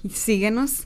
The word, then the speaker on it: Síguenos: Síguenos. – That's how you pronounce it.